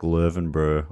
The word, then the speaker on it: Löwenbräu: Maybe the Lauenbrau beer house. At Oktoberfest, the Lauenbrau, or I'm sure it's pronounced totally like Löwenbräu or something,